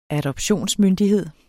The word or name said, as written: adoptionsmyndighed